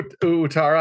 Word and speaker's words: Ouattara: Dango Ouattara, I believe it's pronounced kind of like a W sound.